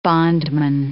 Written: bondman

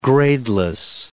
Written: gradeless

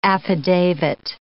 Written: affidavit

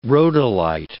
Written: rhodolite

